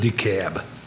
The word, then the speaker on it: DeKalb: Commissioner, how do you actually say it right? – We pronounce it DeKalb.